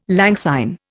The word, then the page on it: lang syne: Indian